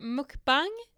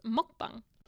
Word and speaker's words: mukbang: Jag tänker alltså prata om eh, mukbang, eller som det egentligen uttalas, mokbang.